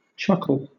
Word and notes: chuckle: British English

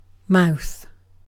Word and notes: mouth: British English

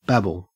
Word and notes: babble: Australian English

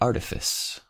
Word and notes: artifice: American English

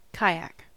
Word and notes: kayak: American English

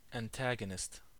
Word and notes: antagonist: American English

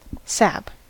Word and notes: sap: American English

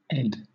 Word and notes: ed: British English

